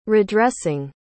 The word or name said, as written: redressing